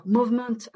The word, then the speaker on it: Movement: Alors, Movement euh, à la manière anglo-saxonne. Donc, Essence, comme ça se prononce en français, tiret Movement. Donc ça c'est un site internet, donc Essence en mouvement.